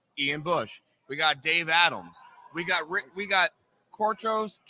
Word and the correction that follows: Cortez: Castro. I know I just butchered it. – Cortez Castro, yes.